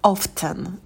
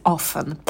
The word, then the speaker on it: often: Often, иногда его произносят как often, звук «т» тоже может быть, но вот я привыкла говорить often.